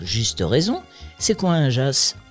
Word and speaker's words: Jas: Cela vous dirait une petite balade, une randonnée dans le Ventoux d'un Jas à l'autre, véritable immersion dans l'histoire du Ventoux. Jas, ça s'écrit J-A-S, mais en provençal, ça se prononce Jas.